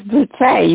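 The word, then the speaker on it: Boutté: It is Boutte. You got it right. – Okay. And it's spelled B-O-U-T-E-E? – T-T-E with an accent on the E.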